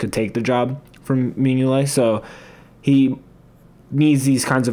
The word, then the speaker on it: Mignolet: because Bruges also has Simon Mignolet, I think is how you pronounce it. – Mignolet.